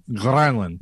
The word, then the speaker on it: Granlund: Say Granlund with the roll. – Granlund. – Ah, yes. Love it.